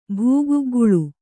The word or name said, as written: ಭೂಗುಗ್ಗಳ